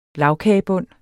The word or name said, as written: LAGKAGEBUND